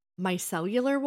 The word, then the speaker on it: micellar: I don't know if I'm going to say this right. – Oh, God. – Micellar water? – Yes. – Is that what it's called? – It? – Yeah, that's okay. That's how you call it.